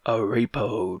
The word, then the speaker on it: Arapaho: You mispronounce Arapaho. – Yes, this is the Araparo tribe. – Arapaho!